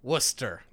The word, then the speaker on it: Wooster: W O O S T E R. Worcester.